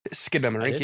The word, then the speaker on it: Skinamarink: I want to see that in Skinamarink. I don't know how to pronounce it. – You said it right. – Skinamarink. You got it.